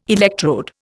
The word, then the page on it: electrode: Indian